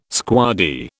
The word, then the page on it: squaddie: British English